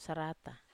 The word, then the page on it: sarata: Wamesa